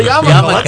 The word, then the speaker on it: yarmulke: Why? Yarmulke rhymes with comical, right? – Yes, it does.